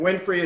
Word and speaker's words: Winfrey: So Max is just M-A-X, and then Winfrey is spelled just like Oprah's last name, W-I-N-F-R-E-Y.